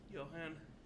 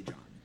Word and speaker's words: Johan: So, on your list of quests and places to explore, you guys now have one more: the port of Johan. Is actually how it's pronounced. – Johan. – It's spelled Johan, they say John.